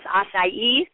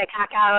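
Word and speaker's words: açaí: We have Genesis Acai. Finally, I'm able to pronounce it. For so long, it was called Acai, Akaka, whatever. I don't know what it was, but it wasn't Acai.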